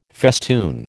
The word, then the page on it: festoon: American English